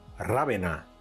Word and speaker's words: Ravenna: La ciudad italiana de Ravenna, Ravenna, si lo pronunciamos en español,